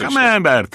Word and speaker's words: Camembert: Proszę państwa, dzisiaj chcielibyśmy porozmawiać o Camembert. Dobrze powiedziałem? Tak. – Ta, ta wymowa Camembert. – Tak, znaczy Camembert, tak.